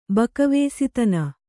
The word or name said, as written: ಬಕವೇಸಿತನ